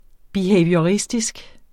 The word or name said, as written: BEHAVIORISTISK